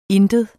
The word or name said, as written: ingen